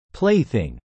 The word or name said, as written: plaything